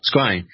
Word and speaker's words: scrying: I know one thing that you've been doing quite a bit lately is black mirror scrying. Screen? How do you say that word? – Scrying.